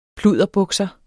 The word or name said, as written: pludderbukser